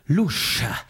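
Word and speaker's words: Luz: Lusch. Ein S in, oder ein Z in Portugal wird ausgesprochen wie ein Sch.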